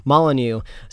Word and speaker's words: Molyneux: Molyneux.